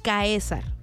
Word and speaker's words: Caesars: C-A-E-S-A-R-S. Caesar. Se pronuncia Caesar, pero es Caesar.